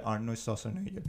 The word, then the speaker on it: Schwarzenegger: Y yo dije, pues tengo que hacer mi asignación, por lo menos tengo que ver la primera de Predator que sale Arnold Schwarzenegger.